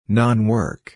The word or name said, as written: non-work